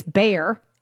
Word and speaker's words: Bayer: Bayer, and we pronounce it here in the States, Bayer, ADRs, which are like shares.